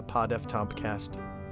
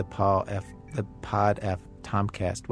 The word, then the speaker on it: Pod F. Tompkast: I just heard the Pod F Tompkast. Wait, I'm saying it wrong. – Yeah, Pod F Tompkast.